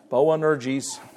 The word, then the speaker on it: Boanerges: How in the world do you pronounce that? You know, not Bojangles. No, Boanerges.